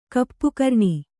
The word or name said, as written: ಕಪ್ಪುಕರ್ಣಿ